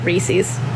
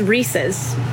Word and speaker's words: Reese's: A pet peeve that I have that I'll never get over is people pronouncing it Reese's. It is Reese's. It is a guy named Reese, and it is his. It's his. Reese's. – Fair. – And it's in the commercial. Reese's Puffs, Reese's Puffs. R-E-E-S-E-S. But it's Reese's. The company is not mispronouncing their own name. It's Reese's.